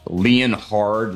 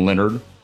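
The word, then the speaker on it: Leonhard: You know who's not on my ass list, and it goes to the AFC East, is Leon Hard Leonard. How do you say his name? He's one of my favorite coaches. Jim Leonard. – Dude, stop. – It's spelled like Leon Hard.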